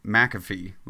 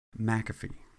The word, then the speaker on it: McAfee: Well, the way that we have always pronounced it is McAfee, like okay. The antivirus software that Everybody knows about, crazy, whatever his name is, McAfee.